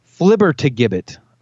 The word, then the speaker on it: flibbertigibbet: Your next word is flibbergibbet. – Flibbergibbet. – Flibbergibbet. – This would be a commonly told tale. – Oh. Actually, hold on. I left out a syllable. It's flibbertigibbet. Flibbertigibbet.